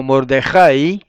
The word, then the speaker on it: Mordejai: Toda palabra que termine, o prácticamente toda palabra que termine así, en ahí, lleva el acento en la A, nunca en la I. Como Mordejai, exactamente.